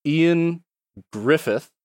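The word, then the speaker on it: Ioan Gruffudd: Ian Griffith, I believe is how you pronounce his name, even though it's spelled in a very different way.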